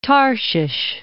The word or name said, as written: Tarshish